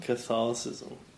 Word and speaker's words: Catholicism: Is it Catholicism or Catholicism? – Catholicism. – So you pronounce the TH. Catholic, not Catholic.